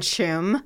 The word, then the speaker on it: Tuam: our ancestry was from Tuam. – Uh-oh. – And she was like, you mean Chum? – Yeah, Chum. – Chum. Okay, next one, E-O-I-N.